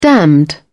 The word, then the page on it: damned: British English